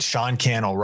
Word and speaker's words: Sean Cannell: Sean Cannell rhymes with YouTube channel.